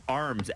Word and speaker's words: Arms: My maiden name was Arms, as in like the body part.